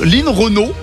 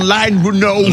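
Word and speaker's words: Line Renaud: Turn right on Line Renault. – Line Renault. On the Renault Line. Non, c'est pas Line, c'est Lynn, c'est son prénom, c'est pas la ligne.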